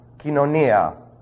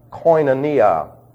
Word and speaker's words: koinonia: The word for fellowship is from kinonia. I will spell it for you. K-E-E-N-O-N-I-A. Sometimes pronounced koinonia. It's not coin. It's kin. The O-I, the omicron iota, is simply a diphthong, which is pronounced as a long E.